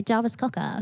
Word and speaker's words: Jarvis Cocker: Jarvis Cocker.